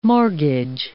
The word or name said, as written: mortgage